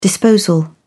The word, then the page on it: disposal: British English